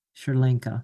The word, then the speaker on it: Sri Lanka: Well, I personally, it's not as extravagant as Sharlanka. Did I say that right? Sri Lanka.